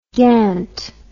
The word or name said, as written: gant